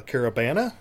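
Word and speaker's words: Caribana: A Caravana? Am I saying it right? – Yes, yes.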